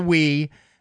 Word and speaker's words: Wii: As you know, Nintendo has joined has joined the fray when it comes to console gaming, and they have introduced the Wii, W-I-I, pronounced Wii.